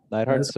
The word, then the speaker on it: Neidhart: Did I say that right, Ben? Neidhart. – Yeah. – Neidhart. So like the famous wrestling family. – Like Jim the Anvil.